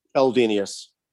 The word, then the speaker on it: Eldenius: Eldenius. – Eldenius. Close enough. Okay, yeah. So it's a long E, Eldenius.